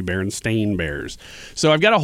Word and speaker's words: Berenstain: Baron, S-T-E-I-N. – That's the way you pronounce it. – No, I remember the E. – You remember the E because there is no E in it. It's Berenstain Bears.